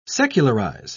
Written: secularize

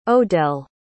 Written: odelle

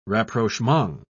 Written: rapprochement

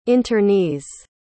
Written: internees

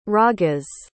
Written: ragas

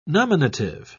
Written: nominative

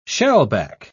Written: shellback

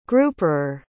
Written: grouperer

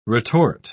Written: retort

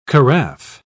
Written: carafe

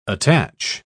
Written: attach

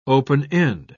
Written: open-end

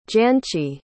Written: Jancsi

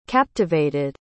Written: captivated

